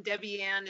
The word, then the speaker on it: Debbianne: Debbie Ann is spelled D-E-B-B-I-A-N-N-E. And my last name is DeRose, D-E-R-O-S-E.